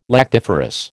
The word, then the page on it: lactiferous: American English